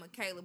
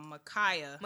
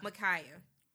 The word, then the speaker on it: Mi’Khia: Because I be saying Michaela, but you pronounced it me? Or no, hold on. Micaiah. – Micaiah.